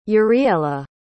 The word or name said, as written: uriela